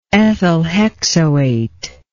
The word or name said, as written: ethylhexoate